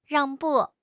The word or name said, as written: 让步